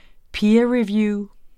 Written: peerreview

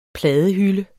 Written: pladehylde

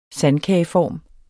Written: sandkageform

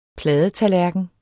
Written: pladetallerken

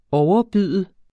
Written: OVERBUDT

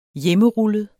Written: hjemmerullet

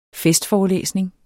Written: festforelæsning

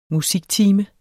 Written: musiktime

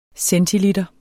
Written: centiliter

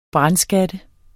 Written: brandskat

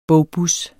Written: bogbus

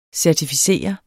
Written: CERTIFICERER

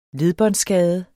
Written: ledbånd

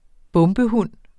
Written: bombehund

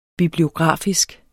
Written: bibliografisk

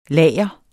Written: lager

